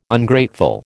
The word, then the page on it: Ungrateful: American English